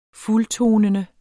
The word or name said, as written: fuldtonende